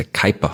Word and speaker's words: Kuiper: Der Kuiper, oder eigentlich heißt es offiziell Kuiper, habe ich auch mal lange recherchiert, bis ich das rausgefunden habe, dass der Kerl sich Kuiper ausgesprochen hat, also der Kuiperbelt, nach dem holländischen, amerikanischen Astronomen Gerard Kuiper.